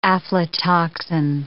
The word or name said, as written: aflatoxin